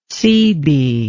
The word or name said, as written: seebee